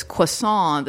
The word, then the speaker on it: croissant: She says, that Beth Baer's character goes, oh, it's croissant. The T is silent.